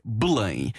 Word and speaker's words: Belém: Voilà, qu'on prononce en portugais Belém.